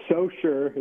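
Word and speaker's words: Saucier: But it's actually, is it Sochet? Is that the way you pronounce that? – It is Socher, is my understanding, as a non-coast local.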